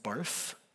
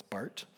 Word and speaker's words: Barth: It looks like it's pronounced Barth, but he's German, so it's Barth.